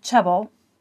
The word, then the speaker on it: chebel: Now, rope in the Old Testament, which is pronounced chebel, but spelled C H E B E L, means that which binds.